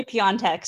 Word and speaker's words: Piontek: Emily Piontek, who is another name I could never pronounce. – Yeah, and it's actually Piontek, so it seems like you never could get it.